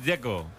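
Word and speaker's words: Džeko: Porque DZ, ¿cómo se pronuncia? – Dzeko. Bruno dice que es Dzeko. – Como tirando a J, Dzeko o algo así.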